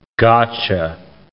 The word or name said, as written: gotcha